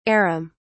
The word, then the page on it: arum: British English